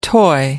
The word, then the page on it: toy: American English